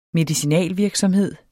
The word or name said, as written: medicinalvirksomhed